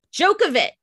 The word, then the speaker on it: Djokovic: Go ahead. Try it again. – Djokovic. – There you go.